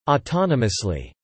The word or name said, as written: autonomously